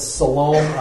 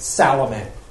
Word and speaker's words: Salome: And Michael said Salome. It's also been pronounced Salome.